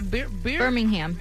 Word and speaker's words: Birmingham: Birmingham.